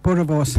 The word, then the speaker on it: Boerewors: Boerewors.